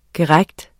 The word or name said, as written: GERIGTENS